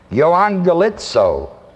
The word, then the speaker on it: euangelizo: Thirdly, is this word, which is spelled E-U-A-N-G-E-L-I-Z, long O, the last O is a long O. Euangelizo.